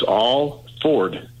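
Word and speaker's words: Alford: And as far as your new congressman coming on, you're pronouncing his name wrong. It's Al Ford.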